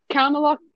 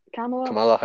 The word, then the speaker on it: Kamala: Kamala Harris. – Or is it? That, yeah, I don't know how to say her first name. – Just think of the wrestler that passed away, unfortunately.